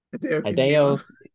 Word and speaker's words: Hideo Kojima: And it was by, how do you pronounce his name? – Hideo Kojima. – Yes. Kojima, thank you.